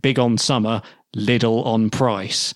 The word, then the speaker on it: Lidl: However, Lidl is pronounced like the city Leeds, a rather long I, like Lidl.